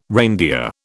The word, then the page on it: reindeer: British English